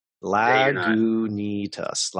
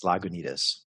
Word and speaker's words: Lagunitas: Lagunitas. Lagunitas.